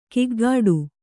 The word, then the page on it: ಕಿಗ್ಗಾಡು: kiggāḍu